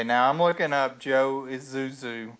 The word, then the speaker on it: Isuzu: Isuzu.